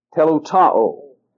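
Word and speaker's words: teleutaō: T-E-L-E-U-T-A-O. Telutao.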